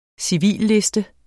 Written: civilliste

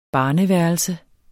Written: barneværelse

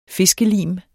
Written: fiskelim

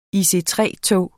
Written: IC3-tog

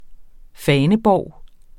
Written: faneborg

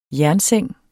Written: jernseng

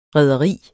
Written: rederi

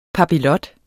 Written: papillot

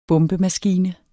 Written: bombemaskine

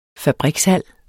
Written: fabrikshal

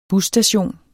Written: busstation

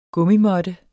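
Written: Gummimåtte

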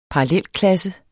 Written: parallelklasse

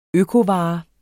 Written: økovare

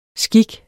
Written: skik

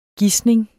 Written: GISNINGERNES

